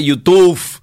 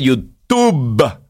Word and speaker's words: YouTube: No se dice YouTube, se dice YouTube.